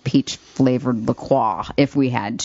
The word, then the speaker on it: LaCroix: LaCroix. I never even took French. LaCroix. – That's the correct pronunciation, but I don't say it that way because I don't want to sound bouge.